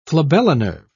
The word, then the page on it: flabellinerved: British English